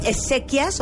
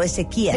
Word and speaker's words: Ezequías: En la, en la I ¿Es Ezequias o Ezequías? Ezequías. ¿Seguro es Ezequías? Sí, sí, tiene acento, por favor.